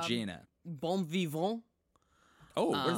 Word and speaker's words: bon vivant: Bon vivant.